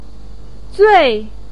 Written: zui4

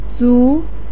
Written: zu2

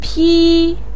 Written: pi1

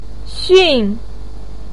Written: xun4

